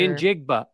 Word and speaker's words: Njigba: Njigba. It's all Njigba. Pronounced. – Njigba. – Every letter basically is pronounced. Like, you look at it and think you're not going to say this letter or that letter.